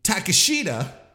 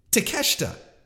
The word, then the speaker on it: Takeshita: You know, gone are the days of calling them Takashita. It's Takesha. It's Takeshita.